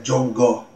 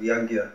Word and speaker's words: Yagya: They'll say Jongo instead of Yagya.